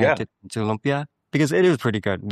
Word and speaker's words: lumpia: Now, is it pronounced Lumpia or Lumpia? – Lumpia, Lumpia. I believe, would be the correct way.